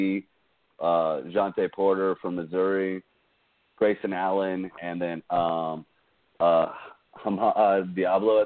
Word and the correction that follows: Diallo: I think I just butchered that. No, yeah, it's Diallo, yeah.